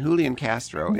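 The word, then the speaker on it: Julián Castro: And then Julian Castro is J with an H, because that's how they say it in Spanish. It's Julian Castro.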